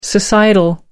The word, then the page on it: societal: American English